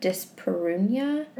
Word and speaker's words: dyspareunia: She diagnosed me with, I don't know how to pronounce this word, but dyspareunia? – Yes, yes.